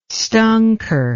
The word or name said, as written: stonker